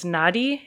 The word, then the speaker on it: Nadi: And there's a woman named Mama, I think it's pronounced Nadi. It's N-A-D-I.